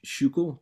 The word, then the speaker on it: Schuylkill: And then kill is a Dutch word meaning river, K-I-L, right? – Yeah. – Yeah, exactly. So they're in Philly, we've got the Schuylkill. It's like actually pronounced Schuylkill, but we call it the Schuylkill. and that means hidden river, S-C-H-U-Y-L, Shu, and then kill means, yeah, hidden river.